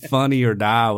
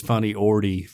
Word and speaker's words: Funny or Die: I thought Funny or Die was Funny Ordy for years.